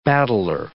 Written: battler

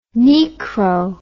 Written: Niekro

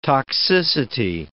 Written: toxicity

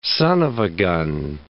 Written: son of a gun

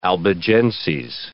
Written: Albigenses

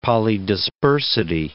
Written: polydispersity

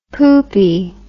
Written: poopy

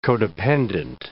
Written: codependent

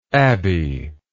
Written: Abbie

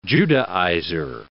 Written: Judaizer

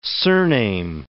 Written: surname

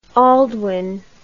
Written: aldwin

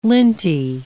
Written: linty

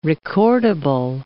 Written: recordable

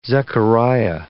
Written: Zechariah

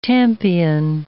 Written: tampion

